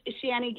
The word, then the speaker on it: Shaney: Oh, it's just Shani Gilbert, just my name. – How do you spell that? S-H-A-N-E-Y? – Yeah, and Gilbert, G-I-L-B-E-R-T.